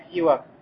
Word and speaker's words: سواك: Ас-сивак произносится с касра ас-син. Так сивак.